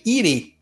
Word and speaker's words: irê: Para entender o erê tem que estar tá moleque, né? Já dizia o, a música. Então, erê vem do Yorubá e significa brincadeira ou diversão. Na verdade, se escreve irê, né? I-R-E.